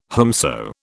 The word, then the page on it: whomso: British English